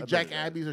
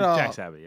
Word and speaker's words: Jack's Abbey: Because I always say Jack Abbey's or Jack's Abbey. But no, they had Jack's Abbey, yeah.